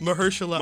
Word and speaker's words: Mahershala: And and you've you've interviewed Michelle Ali. If I said it wrong, please correct me. Mahershala. – Mahershala.